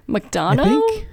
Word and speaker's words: McDonagh: I don't know how to say this guy's name. Martin McDonagh. – McDonough. McDonough? – But McDonough? Yes.